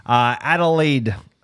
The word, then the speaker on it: Adelaide: Adelaide, Australia.